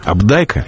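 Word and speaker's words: Апдайка: Когда в 1965 году на нас обрушился «Кентавр», шедевр «Абдайка», с тех пор я привык к ударению на втором слоге, этот роман мгновенно стал культовым, книгой-паролем, позволяющей попасть в заветный клуб понимающих на кухне тех интеллигентных домов, где рождалось общественное мнение 60-х.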